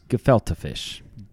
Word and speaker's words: gefilte fish: How would you pronounce that? – It's gefilte fish. – Gefilte.